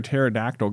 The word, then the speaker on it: pterodactyl: Why can't you hear a pterodactyl going to the bathroom? – I think I know the answer. Is it because the pee is silent? – The pee is silent.